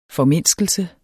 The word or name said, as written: formindskelse